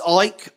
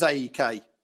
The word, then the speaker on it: AEK: Yeah, it's Ike. I know that's how you say it, John. I always thought it was AEK. But apparently it's Ike. Right. That's fine. – I stand corrected.